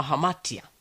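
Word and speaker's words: hamatia (ἁμαρτία): Lakini katika lugha ya Kigiriki, hilo hilo neno chata au makosa linatumika, linatamkwa kama hamatia.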